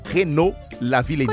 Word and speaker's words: Renaud Lavillenie: Renaud Lavillenie. – Continua. – Renaud Lavillenie. – Continua. – Uma hora depois. – Continua. – Renaud Lavillenie. – Continua. – Duas horas depois. – Renaud Lavillenie.